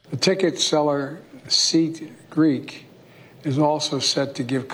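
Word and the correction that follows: SeatGeek: That was Seat Geek, but yeah. Close enough.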